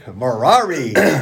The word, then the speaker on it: Merari: Welcome back, everybody, to Amram and Merari Read the Bible. – Yes, I am Merari, and this is Amram. I am here. Amram. Merari rhymes with Ferrari.